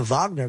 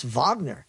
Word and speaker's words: Wagner: there were people pronouncing it the Wagner Group. And so that is it. It's Wagner.